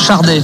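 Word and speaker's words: Sade: Par contre, ça se prononce Chardé, avec un R qui n'existe pas, mais on dit Chardé normalement. Et je crois que d'ailleurs, elle le dit, oui, elle le dit elle-même, Chardé.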